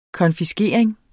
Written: konfiskering

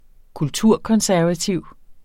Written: kulturkonservativ

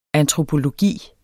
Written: antropologi

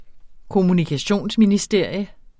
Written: kommunikationsministerie

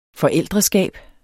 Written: forældreskab

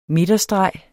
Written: midterstreg

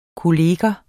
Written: kollega

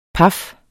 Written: paf

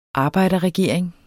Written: arbejderregering

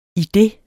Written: idet

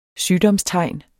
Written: sygdomstegn

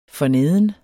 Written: forneden

